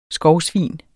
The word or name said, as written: skovsvin